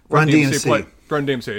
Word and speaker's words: Run DMC: while Christmas in the Hollies by Run DMX plays in the background. – Run DMC, yeah. Sorry, I said it wrong.